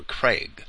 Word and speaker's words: Krieg: Craig, or Craig, K-R-I-E-G.